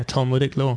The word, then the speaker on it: Talmudic: You studied, and I'm probably going to pronounce this incorrectly, uh, Talmudic law. Is that did I pronounce that somewhat close? – That that is that is exactly yeah, Talmudic law.